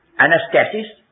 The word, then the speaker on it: Anastasis: Anastasis. Now, my pronunciation is not Greek. My pronunciation is for the poor wretches like ourselves who speak English.